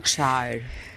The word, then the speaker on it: Pembrokeshire: Pembrokeshire.